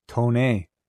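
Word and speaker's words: Toné: Tone. Tone, yes. Tone.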